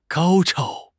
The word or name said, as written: 高超